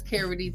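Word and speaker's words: Kerry D: You can follow me on all my streaming platforms as the Carrie D or online as official Carrie D, spelled like Kerry Washington, not Keri Hilson.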